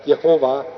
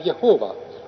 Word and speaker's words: Jehova: J-H-V-H. Ja kuidas seda hääldati, siis meie kasutame seda tänapäev Jehova. Aga see ei ole enam puhas hääldamine, sest juudid võtsid ja kasutasid Issanda täishäälikud, Adonai täishäälikud selle sõnasse ja sellepärast Jehova on juba kahe nime kokkupõiming.